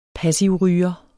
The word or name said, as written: passivryger